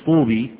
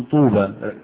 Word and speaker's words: طوبى: طوبى لما تقولش طوبي تقول طوبى، رسمتها ايه؟ ياء ونطقتها ايه؟ الف، والباء عليها ايه؟ فتح.